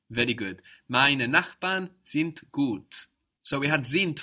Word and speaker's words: sind: And it's spelled S-I-N-D. And again, we have the hard version of the D sounding like a T. Sind. How was the word child? – Kind. – Kind. Again, K-I-N-D, but we hear a T.